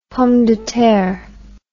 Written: pomme de terre

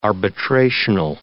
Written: arbitrational

